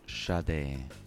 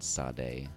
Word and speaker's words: Sade: It is Sade, not Sade.